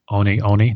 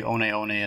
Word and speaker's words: One One: And both she and the club are sponsored by the running shoe company Hoka Oni Oni. Did I pronounce that right? – Yes. – Okay, I nailed it. – Most people will say 1-1, but yeah, right. You say one one, and then you really sort of know what you're talking about.